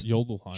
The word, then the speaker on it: Jotunheim: It's a soft J. It's the Jotunheim. – It's Jotunheim.